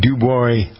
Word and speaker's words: Dubois: Long-standing Dubois, I think it's pronounced Dubois, D-U-B-O-I-S. Is it Dubois or Dubois County?